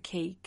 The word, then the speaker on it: Quay: It's spelled Quay, the Quay restaurant, not the, just Quay.